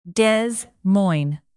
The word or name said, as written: Des Moines